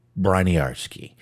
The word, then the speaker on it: Briniarski: Well, like I said, I'm really going to go ahead and just kind of get going here and start talking about Andrew Briniarski. And I did figure out that is the way you say his name.